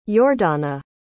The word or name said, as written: yordana